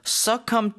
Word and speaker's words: succumb: The spelling is succumb. S-U-C-C-U-M-B. Mm-hmm. Succumb to something. – B는 발음이 안 되는 거죠, 그렇죠? – Yes, right. That's right.